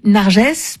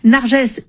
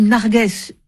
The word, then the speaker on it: Narges: La semaine dernière, la prix Nobel de la paix Narges Mohammadi a été appelée Narges pendant quelques heures parce qu'il y a eu un doute en conférence de rédaction du matin et que l'équipe a choisi de trancher pour une prononciation. Ce n'était pas la bonne. Nargès est redevenue Nargès dans l'après-midi et nous sommes nombreux à penser à elle et à sa famille.